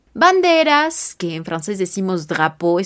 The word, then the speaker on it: drapeau: En francés, le drapeau, D-R-A-P-E-A-U, y si hablamos en plural, le agregaremos una X al final para decir las banderas, le drapeau, pero no habrá impacto a nivel pronunciación.